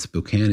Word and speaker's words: Spokane: And this listener, Jeremy, wrote in and said, I just want you to know that Spokane is basically a desert and it's pronounced Spokane.